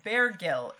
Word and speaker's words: Bergil: as in the homonym of Barragil from our reading last chapter. – Not even Barragil. Bergil. It is two syllables. It's just Bergil.